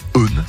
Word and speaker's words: hen: On peut dire Eun, ça s'écrit H-E-N.